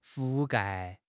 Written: 覆盖

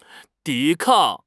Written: 抵抗

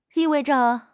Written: 意味着

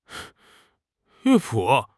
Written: 乐谱

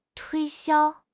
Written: 推销

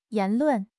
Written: 言论

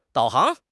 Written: 导航

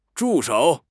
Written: 助手

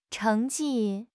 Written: cheng2 ji4

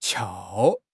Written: 翘

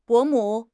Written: 伯母

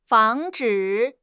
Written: 防止

